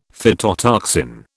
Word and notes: phytotoxin: British English

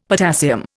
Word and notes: potassium: Indian

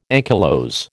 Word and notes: ankylose: American English

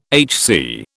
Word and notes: HC: British English

